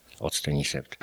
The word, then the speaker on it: Octenisept: W tej chwili jest taki złoty środek, nazywa się Octenisept, pisze się przez OC. Octenisept.